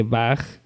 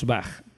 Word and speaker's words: Bach: De hecho, ni siquiera sería incorrecto decir Bach. Yo personalmente no corregiré a nadie que diga Bach, pero lo lógico es pronunciarlo tal y como suena en el idioma original.